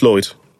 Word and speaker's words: Lloyd: Is it Lloyd or Lloyd? – Just Lloyd. – But it's got two L's.